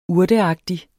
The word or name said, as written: urteagtig